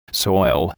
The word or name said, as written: soil